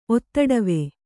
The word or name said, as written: ಒತ್ತಡವೆ